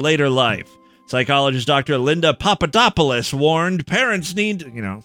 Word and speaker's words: Papadopoulos: I pronounced Papadopoulos right.